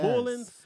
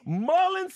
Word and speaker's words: Mullins: Mullins, South Carolina. – Ooh, the way you pronounce it, it's giving. – Is that the correct pronunciation? – No. – What is it? – Mullins.